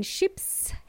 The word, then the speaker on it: ship: I'm not talking about sheep, domesticated animals, okay? It's a short E sound. Ship or ships in plural.